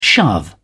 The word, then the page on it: shove: British English